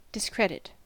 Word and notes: discredit: American English

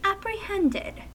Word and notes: apprehended: American English

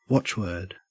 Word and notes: watchword: Australian English

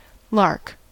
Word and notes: lark: American English

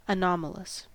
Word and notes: anomalous: American English

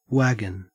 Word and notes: wagon: Australian English

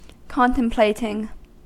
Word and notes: contemplating: American English

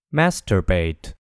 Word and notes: masturbate: American English

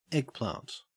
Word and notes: eggplant: Australian English

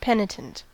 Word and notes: penitent: American English

